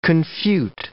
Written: confute

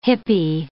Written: hippy

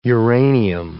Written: uranium

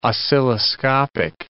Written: oscilloscopic

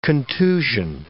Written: contusion

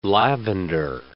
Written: lavender

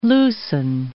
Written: loosen

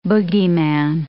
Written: bogeyman

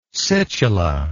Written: situla

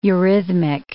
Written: eurhythmic

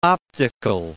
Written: optical

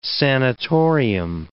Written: sanatorium

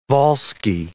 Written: Volsci

